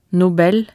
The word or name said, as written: Nobel